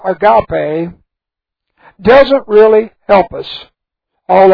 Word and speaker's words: agape: The Greek word is agape. A-G-A-P-E long E. Agape.